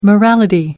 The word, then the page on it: morality: American English